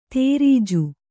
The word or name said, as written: ದರ್ಜೆ